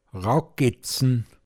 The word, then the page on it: an Stus zåmmre(d)n: Pinzgauer Mundart